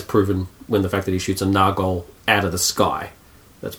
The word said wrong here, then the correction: Nazgul: A flying Nargol. Or Nargul. How do you pronounce it? – Nazgul. – Nazgul. That's what I'm thinking of.